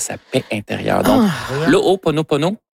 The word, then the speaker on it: Ho'oponopono: Non, c'est une philosophie hawaïenne de, je vais le prononcer comme il faut, de l'O-O. Ho'oponopono, le Ho'oponopono,